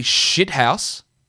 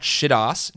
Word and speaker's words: shithouse: is that he has some absolutely shithouse. Remember the pronunciation of that word. Shithouse, not shit-ass. Shit-arse.